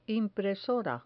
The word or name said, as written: impresora